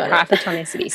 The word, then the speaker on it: Hypertonicity: Hypertonicity? – Yes, yep, that's it. Hypertonicity.